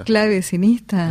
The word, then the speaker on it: clavecinista: ¿cómo se dice? Claviconista. – Clavecinista. – Clavecinista, sí, que toca la clave.